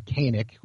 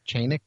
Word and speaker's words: Canik: The gun of the year 2 years ago was a Canik, or Chanik, depending on whose pronunciation you go for.